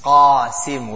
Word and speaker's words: Qasim: So example, a person's name will be Qasim. We say Qasim, but in Arabic, the person's name is Qasim with a Qaf. So that's Qasim.